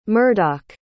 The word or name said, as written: murdoch